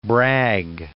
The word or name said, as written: Bragg